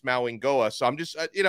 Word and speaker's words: Mauigoa: But at the same time, Blue would not surprise me whatsoever if Maui Goa is, and actually, let me try to pronounce his name correctly. I've been corrected on this twice. Mauingoa, I think is how you say his name oh, correctly.